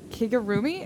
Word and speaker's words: Kigurumi: So I'm going to totally mispronounce this, but like I ordered a Kigurumi. Is that how you say it? – Yeah, no, you pronounced it correct.